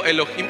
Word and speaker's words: Elohim: Su pronunciación es como si estuviéramos diciendo Elohim con una J, Elohim, Eloah, Elohim.